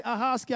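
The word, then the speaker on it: Ahoskie: Am I saying that right? Ahoski? Ahoski? Ahoski? I knew y'all would correct me. That's all right. That's all right. Ahoski.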